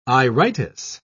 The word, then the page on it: iritis: British English